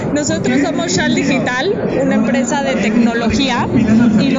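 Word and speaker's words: Xal Digital: Sí, no se produce con Che. Se produce con S. Se escribe con Z. No, mentiras. Con X. Sal Digital. Pero se dice Shal Digital. Entonces es X-A-L Digital.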